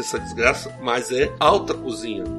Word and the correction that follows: haute cuisine: Haute cuisine. – Haute cuisine. Eu não consigo fazer o bico.